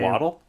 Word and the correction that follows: Yaddle: It's not Yaddle? – Yaddle, sorry.